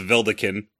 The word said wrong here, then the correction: Vedalken: Oof. – Oh. Yeah, that one's just wrong. – I know. I transpose the letters.